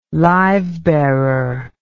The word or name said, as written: livebearer